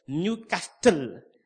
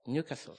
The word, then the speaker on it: Newcastle: In fact, Newcastle today, I said that, I was saying Newcastle. That's the way I used to pronounce it. But that friend will tell me, no, that's not the way. You don't pronounce it like this. You are going to be a laughing stock. You don't do that. You cut it off, Newcastle.